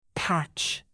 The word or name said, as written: patch